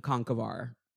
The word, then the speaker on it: Conchobhar: For the non-Irish listeners, Connor is spelled more properly C-O-N-C-H-O-B-H-A-R, which is pronounced in older Irish Conkavar or Conkavar. They dropped the middle syllable somewhere along the line, and it's now just pronounced Connor, but it's spelled Conchabahar.